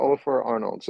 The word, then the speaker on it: Ólafur Arnalds: Well, check out Ólafur Arnalds.